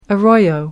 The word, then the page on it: arroyo: British English